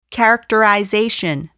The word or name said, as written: characterization